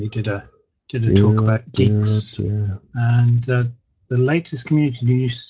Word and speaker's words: Guix: That was Geeks, no. By the way. G-U-I-X. – Oh, Geeks. – Yeah. Pronounced Geeks.